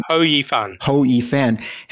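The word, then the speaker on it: Hou Yifan: Ho Yifan has decided to no longer play in women's only events.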